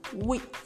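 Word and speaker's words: huit: 8 is wheat. It's pronounced like the wheat, the food wheat that people make into swallow, or some people use it as a form of for pap or something.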